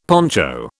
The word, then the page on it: poncho: British English